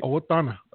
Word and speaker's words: Owatonna: Owatonna.